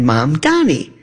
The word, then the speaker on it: Mamdani: His name, Mom and Dad, Mamdani. My mom, Donnie, you know, my mom's name is Donnie.